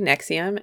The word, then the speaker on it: NXIVM: Okay, so the podcast is called Escaping Nexium, and the way you spell that is all caps NXIVM.